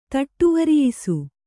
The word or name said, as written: ಥಟ್ಟುವರಿಯಿಸು